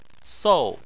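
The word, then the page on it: sou: tone 4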